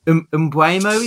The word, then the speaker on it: Mbeumo: Mbeumo, is it? – Yeah.